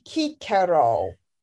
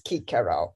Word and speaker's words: Cicero: Well, it would be Cicero is how he would have pronounced his name, is Cicero.